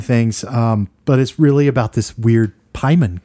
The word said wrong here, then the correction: Paimon: Did I say that right? Paimon. – Paimon. Yeah, Paimon.